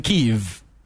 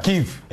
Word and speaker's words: Kyiv: I was there in Kyiv. That's how come I can pronounce it. All of you are saying Kyiv, Kyiv. I was there in Kyiv. – That's right. Kyiv.